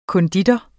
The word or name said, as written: konditor